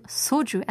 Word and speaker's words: 소주: 피디님께서 You're a real Korean, pronouncing soju as soju. 몰랐어. I wasn't sure. I thought 저를 지적하는 건가 몰랐는데 그쵸? 소주가 원래 발음이어야 되지만 어, 이소리 들어가잖아요. 한국 분들은 소주라고도 하죠.